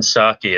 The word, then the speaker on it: Psaki: The P in Jen Psaki is silent, just like the P in President Biden, by the way.